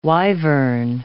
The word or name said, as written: wyvern